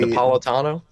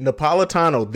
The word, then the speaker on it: Napolitano: But to get back on this whole DACA thing, the former Homeland Security Secretary Janet, and I'm going to murder her last name, Napa Latina, whatever. Janet, that's what we're going to call her. If you know how to say it better than me, say Napolitano. – It. Napolitano. There you go.